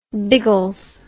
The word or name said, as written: biggles